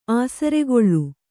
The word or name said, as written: ಆಸರ್ಗಳೆ